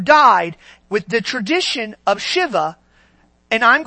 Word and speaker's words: Shiva: Am I wrong or did I say that right? Shiva, right? Shiva. Not Shiva, Shiva.